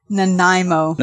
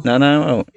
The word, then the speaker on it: Nanaimo: And you're located in Nanaimo, British Columbia. How do you pronounce that? – Nanaimo. – Nanaimo.